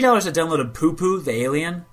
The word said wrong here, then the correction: Pew Pew: I think it's actually Pew Pew, like laser gun is how it's pronounced.